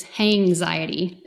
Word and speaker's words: Hanxiety: Okay, wait, what, spell that word then with an H? – Uh-huh, like anxiety, but it's Hanxiety.